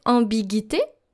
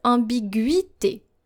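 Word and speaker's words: ambiguïté: However, the tréma here, the double dots on the letter I, cancels that silence. So basically, you're supposed to pronounce the U. So don't pronounce it ambiguité, but pronounce it ambiguité.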